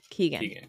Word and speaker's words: Keoghan: Keoghan, yeah.